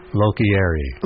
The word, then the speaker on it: Locorriere: This is Dennis LaCroix? – No, it's Lochieri. – Lochieri, yeah. Okay.